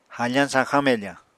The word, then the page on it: hafañ sañxañ mefañ: Apatani